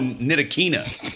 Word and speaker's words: Ntilikina: Ntilikina. Yeah. Ntilikina. No, Ntilikina. I pronounced it like I used to call Fred Van Fleet.